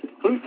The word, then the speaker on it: Clute: No, it's just Clute, Texas.